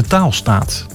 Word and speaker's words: Taalstaat: Nou, dat is ook zo'n zo'n zo'n uitspraak die je op twee manieren kan in. Taal staat, dus taal staat uh-huh. Mhm. of taalstaat. De staat waar taal wordt gedaan.